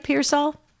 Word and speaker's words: Pearsall: Did I say it right, Pearsall? – Yes, uh-huh.